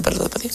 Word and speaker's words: Perla Tapatía: Se llegó el día, querido Gustavo Mendoza, del encuentro de México contra nuestros hermanos coreanos allá en la Perla Tapatía. ¿Sí se llama Perla Tapatía? Sí. Muy bien. Lo dije bien.